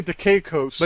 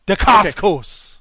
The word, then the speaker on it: Dacascos: president, played by Louis Gossett Jr., turns to maverick scientist Lucas Foster, who is Mark Dacascos, for help. – I believe With it's Mark Dacascos. – Is it I always say Dacascos. – But you got to say it like Dacascos. – Okay. Mark Dacascos.